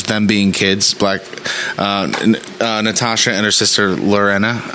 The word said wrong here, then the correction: Yelena: Am I saying her name right? Yelena. Natasha and Yelena.